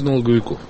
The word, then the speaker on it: налоговиков: А вот Владимир Путин считает, что это слишком либеральная мера, что снова Следственный комитет может такие дела открывать без представления налоговиков. Или налоговиков? – Лучше налоговиков. – Да. Извините, пожалуйста. Да, это профессиональное ударение.